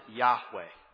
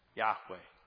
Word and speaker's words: Yahweh: But God condescends and reveals the name, we would pronounce it Yahweh. Yahweh.